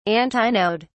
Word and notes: antinode: British English

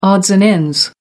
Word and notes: odds and ends: American English

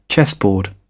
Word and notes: chessboard: British English

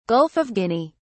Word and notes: Gulf of Guinea: British English

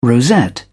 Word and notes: rosette: British English

